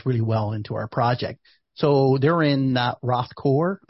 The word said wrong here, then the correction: Rathgar: I'm not sure if I'm getting it right. – It's probably Rathgar.